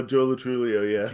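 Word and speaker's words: Joe Lo Truglio: And even just watching how do you say his name? Latrulio? – Oh, Joe Latrulio, Joe Latrulio.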